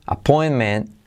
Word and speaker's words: appointment: I have a doctor appointment, appointment, la T de appointment, casi no se dice appointment tomorrow.